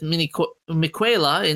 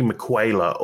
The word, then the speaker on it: Miquela: You know, just a thought, is it actually Miquela or is it Miquela? – I think it's Miquela, but Miquela. – It's spelled with a Q, but I think it's pronounced with a K.